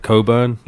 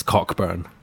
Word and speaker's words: Cockburn: But the name is overly pronounced on purpose. Because you would pronounce it like Coburn. Like Colbert. – Oh, okay. – But it's Cockburn.